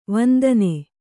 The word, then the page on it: ವಂದನ: Kannada